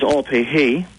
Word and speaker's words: Opihi: So another set of rivers further south, the Opihi, you know, which is Opihi, pronounced correctly. So O is place of, Pihi, growth, place of growth.